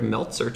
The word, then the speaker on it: Meltzer: Um, and to talk about both those things, I'm very happy to introduce Eric Meltzer to the show.